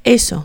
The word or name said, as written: eso